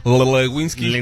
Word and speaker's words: Lewinsky: No se pronuncia así. Es Mónica Lewinsky. – O es Mónica Levidininsky, también. – Lewinsky.